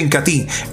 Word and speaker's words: Incatea: Incatí, se escribe Incatea, pero se pronuncia Incatí, en presentación de medio litro por solo 39,000 pesos, por solo 39,000 pesos.